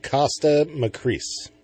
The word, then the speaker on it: Kosta Makreas: Kosta Makreas.